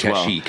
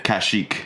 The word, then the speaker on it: Kashyyyk: I know the answer to this as Kashyyyk. – Well. – Kashyyyk. – Kashyyyk.